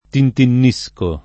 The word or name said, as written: tintinnare